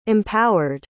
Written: empowered

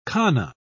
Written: kana

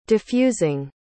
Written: diffusing

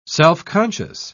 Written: self-conscious

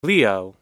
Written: leo

